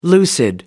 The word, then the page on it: lucid: American English